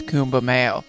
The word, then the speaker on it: Kumbh Mela: or I'm sure they're mispronouncing it and I'm saying it right. I think the way that they're supposed to say it is Kumbh Mela.